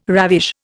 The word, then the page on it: ravish: Indian